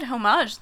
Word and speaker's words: homage: You said homage the other day. – Homage? – Which is improper. – Homage? Homage? – Isn't it homage? The H is silent.